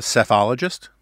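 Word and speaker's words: psephologist: and one might even call him a cephologist. You can look it up. Just note the P is silent.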